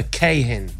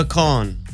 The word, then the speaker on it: Kahan: It's neither, actually. – What is it now? Kahan. – It's Noah Kahn. – No. – Oh, yes. – It is. – Hello. – It's Noah Kahn. Okay. The second A is silent.